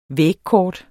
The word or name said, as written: vægkort